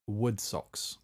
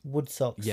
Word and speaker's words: Wodsox: So I came across a company called, I believe it's pronounced Woodsocks. – Woodsocks.